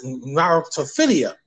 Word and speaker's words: narratophilia: Anyhow, that's narratophilia. That's how you pronounce it.